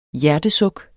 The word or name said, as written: HJERTESUKKENES